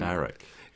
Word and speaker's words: Barack: surely if you've heard it once, you know it's Barack. So why would you pronounce it? I mean, that's the guy's name. – Barack.